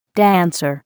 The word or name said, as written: dancer